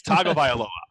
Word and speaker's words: Tagovailoa: Tagovailoa. It's Tagovailoa. I know how to say it. I'm not going to be like the guy at the Heisman ceremony or Stugatz. It's Tua Tagovailoa.